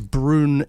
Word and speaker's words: Brown: Brown is actually a Scottish surname and often pronounced as Brune in Scotland.